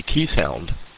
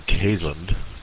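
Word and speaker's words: Keeshond: he was a Keese hound, or the correct pronunciation, Kaysund.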